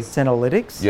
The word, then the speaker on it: senolytics: So I want to make sure I pronounce it right. Senolytics. Am I saying that correctly? – Senolytics? – Yes.